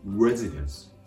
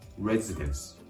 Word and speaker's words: residence: 発音のポイントは語頭の R の発音です。英語の R の発音は発音する直前小さく「う」をように residence, residence と発音するところがポイント。これを L の発音と間違えないように気をつけてください。